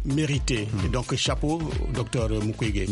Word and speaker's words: Mukwege: Où, pour rencontrer d'abord beaucoup d'artistes, mais aussi voir ou interviewer monsieur le docteur Mukwege. – Mukwege. – J'espère que je prononce bien le nom. – Trop bien, hein? Trop bien.